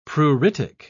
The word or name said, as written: pruritic